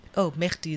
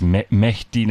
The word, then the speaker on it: Mehdi: Mehdi, is that how you say it? – I think it's Mehdi.